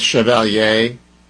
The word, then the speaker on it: Chevalier: And I pronounce it the French way, Chevalier. I don't know if it's Chevalier, like the way, you know, but think about Chevrolet. That's the way I'm going with it.